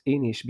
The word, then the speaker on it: Inis: Well, uh, so to to reassure you, I pronounce most of the time I pronounce it Inis too. okay, but yeah, uh, it, it, should be pronounced Inish because it's a Gaelic word. It's uh, Irish.